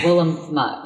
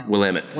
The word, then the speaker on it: Willamette: What in the I always say this wrong. Willamette, right? – No. Willamette. – Willamette. – Damn it, Willamette. – There you go. Now I'll get it.